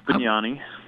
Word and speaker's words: Benani: Peter Benani is my guest, and I probably, because I'm not Italian, butchered your name, Peter, so I'll let You're pretty close, Benani.